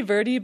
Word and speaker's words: Verde: Do you like the Monteverde or Verde? – I say Verde, but most people say Verde.